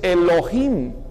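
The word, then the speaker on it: Elohim (אלהים): Y dioses se dice Elohim. La terminación him es el plural masculino en el idioma hebreo.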